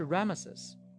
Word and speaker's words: Ramesses: 兰塞是简化过的音译，原文的发音是 Ramesses，所以很多学者把出埃及记的时间推迟了 200年，认为是在 19 王朝的国王 Ramesses 二世的任上。